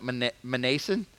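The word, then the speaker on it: Mnason: Manasin. It's like Mason, but they slipped in an N.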